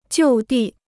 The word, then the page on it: 就地: jiù dì